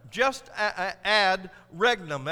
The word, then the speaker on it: jus ad regnum: Regnum, just ad regnum, that's how you say it, J-U-S-A-D-R-E-G-N-U-M, just ad regnum in the Latin.